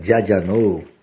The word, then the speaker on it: Adjanou: Então, Thiago, o nome de Adjanou, como mesmo se pronuncia, Adjanou, assim que pronuncia, tá?